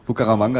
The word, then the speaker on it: Bucaramanga: Kommt an aus Bucaramanga. – Hast du richtig ausgesprochen. – Danke. – Fast. – Also, Bucaramanga liegt in der Mitte von riesigen Ananasplantagen.